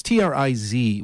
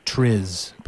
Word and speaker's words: TRIZ: And before we get to Jack's bona fides, Jack, you pronounce it sort of, it's TRIZ, which is the sort of Western acronym for this long, drawn-out Russian phrase. And all my colleagues at the National Science Foundation say TRIZ, but you were telling me before it's TRIZ?